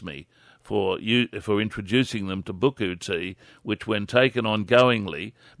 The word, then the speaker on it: Buchu: One thing you need to start using and possibly never go off, it's what's called Buku tea, and I'll spell it for you. – Thank you. – B-U-C-H-U. Buku? – B-U-C-H-T. – Yeah, B-U-C-H-U. Buku tea.